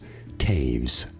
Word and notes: Toews: Welsh surname

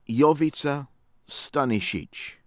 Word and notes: Jovica Stanisic: American English

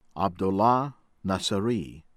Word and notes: Abdollah Nasseri: American English; from Iran

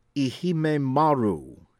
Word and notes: Ehime Maru: American English

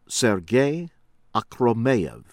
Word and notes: Sergei Akhromeyev: American English

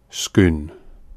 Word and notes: Scone: place name

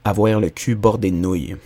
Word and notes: Avoir le cul bordé de nouilles: français; Québec

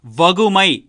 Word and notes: வகுமை: Tamil

